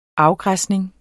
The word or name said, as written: afgræsning